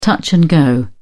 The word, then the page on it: touch and go: British English